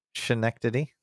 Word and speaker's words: Schenectady: Schenectady.